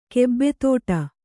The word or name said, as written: ಗೂಬೆತಡೆ